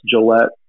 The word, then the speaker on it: Gillette: So I'll tell them, no, it's Gillette, like the razor, so that they know it.